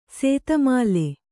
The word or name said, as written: ಸೇತಮಾಲೆ